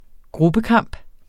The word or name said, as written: gruppekamp